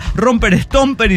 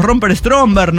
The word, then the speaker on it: Romper Stomper: Romper Stomper, es más, lo pronunciamos como el culo toda la vida porque era Romper Stomper y nosotros le decíamos Romper Stromber, no sé qué pedo.